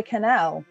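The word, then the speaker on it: Kanell: Jenna Connell.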